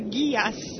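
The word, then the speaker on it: Geass: Mi piace perché l'Ilaria dice ditele che si pronuncia Gias. – Sì, Code Gias, però vabbè, non lo pronuncia mai bene nessuno.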